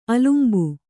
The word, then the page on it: ಅಲಂಬು: alambu